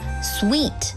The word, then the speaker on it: suite: 如果是多人旅行或者是全家出游，你还可以考虑订上一间套房 suite，注意发音是 suite S-U-I-T-E suite。